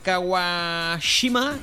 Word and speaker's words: Kawashima: En séptima posición, el Brain Training del Dr. Kawashima. ¿Lo he dicho bien? – Sí, se lo has dicho bien.